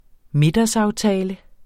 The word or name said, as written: middagsaftale